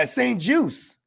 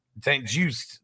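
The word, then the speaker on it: St-Juste: That uh, that Saint Just Justy yeah, St. Juice. St. Juice. There you go. Yeah. That's how you pronounce it.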